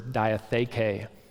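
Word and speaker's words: diatheke (διαθήκη): a good Erasmian pronunciation of this word is diatheke in the Greek.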